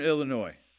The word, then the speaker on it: Illinois: Northern Illinois. No S. Don't pronounce the S.